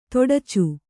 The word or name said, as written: ಥಟ್ಟುಚ್ಚು